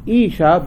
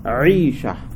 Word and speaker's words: Aisha: And whenever you pronounce this word or any other word with the Ain, the letter Ain, we should try to pronounce Ain. It's a guttural letter. It should not be resembling to Hamza sound. Not Isha, but Aisha. Aisha.